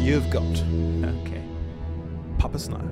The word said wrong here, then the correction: Pape Sow: Uh huh. Pape Sow.